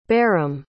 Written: barram

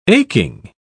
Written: aching

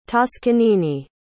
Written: toscanini

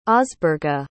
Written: osberga

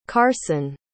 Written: karson

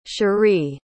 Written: charee

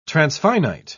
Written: transfinite

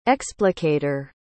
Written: explicator

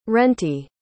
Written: rentee

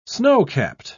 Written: snowcapped

